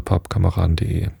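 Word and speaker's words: Pubkameraden: Pappkameraden schreibt man übrigens P-U-B-Kameraden. Das ist so ein Wortspiel. Es geht nicht um Pappe und Pappkameraden, also Kameraden aus Pappe, sondern um die Kneipe Papp, also die englische Kneipe, den Papp.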